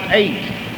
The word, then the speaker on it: eis: It's translated from the Greek word "eis," pronounced "ace."